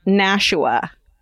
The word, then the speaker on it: Nashua: New Hampshire town is pronounced Nashua, not Nashua County or Nashua.